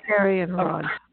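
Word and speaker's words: Arianrhod: So, but, and of course, one of the interesting things that, because you mentioned, now it's, how do you say Ariadnic or Arianrod.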